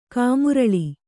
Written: ಕಮ್ಮರಲ್

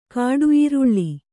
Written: ಕಾಡುಈರುಳ್ಳಿ

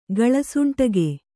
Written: ಗಳಸುಂಟಿಗೆ

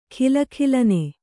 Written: ಘಲಘಲನೆ

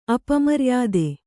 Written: ಅಪಮರ್ಯಾದೆ

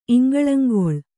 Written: ಇಂಗಳಂಗೊಳ್

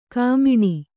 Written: ಖಮಣಿ

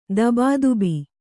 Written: ಧಬಧಬೆ

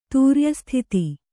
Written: ತೂರ್ಯಸ್ಥಿತಿ

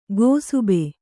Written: ಗೋಸುಬೆ

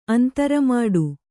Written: ಅಂತ್ರಮಾಡು

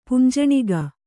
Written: ಪುಂಜಣಿಗ

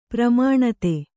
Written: ಪರಿಮಾಣತೆ

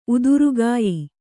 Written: ಉದುರುಗಾಯಿ

